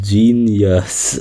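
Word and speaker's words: Jeen-yuhs: It's called Genius, and I hate the name. It's so bad. It's not even... It's spelled like J-E-E-N, then Y-U-H-S.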